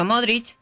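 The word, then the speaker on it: Modrić: Pero es con CH. – Por eso, Modric. – Claro, se pronuncia con CH.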